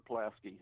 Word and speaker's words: Pulaski: It's not pronounced Pulaski like it is in Chicago, isn't it? Pulaski or something? – Yeah. It is. It is Jasper Pulaski.